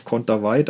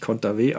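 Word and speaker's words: Kontaveit: im deutschen TV auf Konterweit, aber die spricht sich Konterwey aus, äh, sagt sie selbst zumindest.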